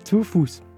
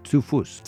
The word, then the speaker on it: Zu Fuß: Zu Fuß. – Zu Fuß. – Zu Fuß.